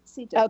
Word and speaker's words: Sita: No, it's right, Sita.